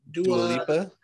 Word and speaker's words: Dua Lipa: Dua Lipa? – Thank you.